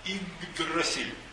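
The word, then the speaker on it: Иггдрасиль: У германцев это был ясень, который назывался длинным словом Игдрасиль. По-честному он пишется с двумя Г и с двумя Д. Как это можно произнести? Игдрасиль.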